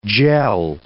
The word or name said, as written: gel